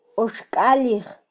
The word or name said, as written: oxkalij